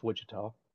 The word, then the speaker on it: Wichita: One, it's pronounced Wichita. – Two, oh, okay. Wichita.